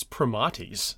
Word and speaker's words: primates: Um, the order is um, well, I first I said primates, but I guess it's primates because of the way that Latin works.